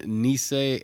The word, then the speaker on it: Nice: That article, though, talked about a woman was beheaded and 2 others were stabbed to death inside the Notre Dame Basilica in Nice. And I think I'm saying that right. N-I-C-E, or Nice, if you're American.